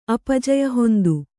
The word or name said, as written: ಅಪಜಯಹೊಂದು